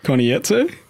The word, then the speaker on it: Konietzko: So, Michael DiMartino and Brian Konietzko. – You mean M. Night Shyamalan. – Is that how you say his name? Konietzko? – Konietzko.